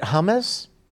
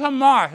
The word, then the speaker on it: Hamas: Isn't it hummus? – No, it's just Hamas. It's the same thing that she's saying, right, Jimmy? – Yeah, it's pronounced the exact same way.